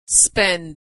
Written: spend

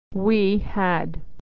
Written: we had

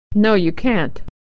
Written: can't

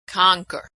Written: conquer

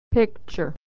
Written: picture

